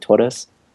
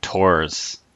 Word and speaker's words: Torres: Like you roll the R, so it'd be like Torres. – Torres. Puerto Rico. – And I'm probably even announcing that or pronouncing that like wrong still, even though I'm trying to pronounce it quickly. – I'm glad I got it right because for the longest time I thought it was just like Tors.